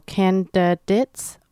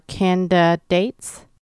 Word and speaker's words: candidates: And so for the ending, you can pronounce it as day or dit, so candidates or candidates.